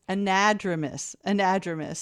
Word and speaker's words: anadromous: I mispronounced it. It's anadromous. So word for the day, if you're playing Scrabble or want to try to stump your friends.